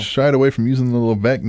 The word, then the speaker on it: Levesque: Levesque.